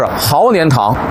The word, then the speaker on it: 鹤年堂: 比方说呢，北京有一个老字号的药铺叫鹤年堂，北京人一念都是豪年堂。